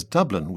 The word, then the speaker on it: Dublin: Patrick Kavanagh, on the other hand, pronounces it as Dublin with two syllables.